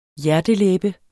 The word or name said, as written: hjertelæbe